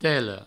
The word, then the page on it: tela: telo